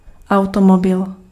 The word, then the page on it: automobil: /aʊ̯tɔmɔbɪl/